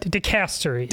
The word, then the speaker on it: dicastery: There's a word that I asked how to pronounce. Dicastery? Am I saying that? – Dicastery. – Dicastery.